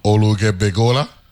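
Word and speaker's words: Oluge Befola: So L-U-G-E is one syllable. – Right. – Olug Be... – Fola. The E is silent after the G.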